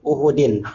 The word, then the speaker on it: Uhud: Faqad jaraha yawmu ahadin. – Masa yaum ahad? Ya, hari minggu gitu? – Yaum ahad. – Ah, udah hari minggu gitu, kenaknya ya? – Oh. Faqad jaraha yaum uhudin. – Hari ahad, hari uhud. – Uhud.